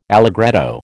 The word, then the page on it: allegretto: American English